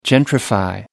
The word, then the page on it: gentrify: American English